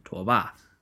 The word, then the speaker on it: Tuoba: I'll be going ahead and using the Mandarin pronunciation, Tuoba.